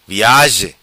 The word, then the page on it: Viazi: patua